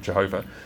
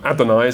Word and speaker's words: Adonai: shouldn't call it Jehovah, Adonai is how the Hebrews would have pronounced it, L-O-R-D, capitals in your Bibles.